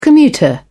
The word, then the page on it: commuter: British English